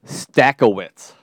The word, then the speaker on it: Stakowitz: it is not really Stakowitz. It is, well, it's spelled the same, but true Polish pronunciation, it's like Stachowicz, Dachowicz, you know.